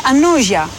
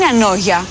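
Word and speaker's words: Ανώγεια: Είναι και το γάμα, είναι ανόζια. Δεν είναι ανόγια, είναι ανόζια.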